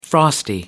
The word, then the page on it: frosty: American English